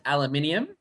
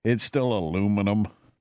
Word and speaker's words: aluminium: And it's aluminium. – Aluminium. Well, yeah, that's what you guys have. I do like, I've always liked the way that's pronounced. In Canada, though, it's still aluminum.